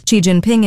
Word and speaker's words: Xi Jinping: Xi Jinping in Beijing for high-level talks on Thursday. – Hold on, she said the name right.